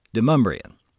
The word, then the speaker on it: de Montbrun: In Middle Tennessee, we pronounce his last name de Montbrun.